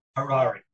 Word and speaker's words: Harary: Harary.